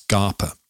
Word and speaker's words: Scapa: S-C-A-P-A, everyone, but it's pronounced Scarpa.